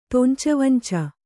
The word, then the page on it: ತೊಂಚಾವಂಚ: tonca1 vanca